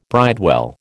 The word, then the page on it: bridewell: American English